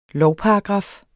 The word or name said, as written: lovparagraf